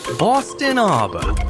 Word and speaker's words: Bawstonaba: It's spelled B-A-W-S-T-O-N-A-B-A. Or as the locals might say, Boston Abba.